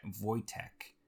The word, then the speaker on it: Wojtek: So Wotjek, is spelled Wojtek. – Oh, I got close. – Sometimes spelled Wojtek, Voytek.